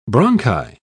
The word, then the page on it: bronchi: British English